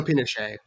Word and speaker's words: Pinochet: Anyway, so this movie is about, and I'm going to get pronunciation wrong probably, Pinochet? Dictator? – Augusto Pinochet.